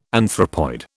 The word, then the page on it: anthropoid: British English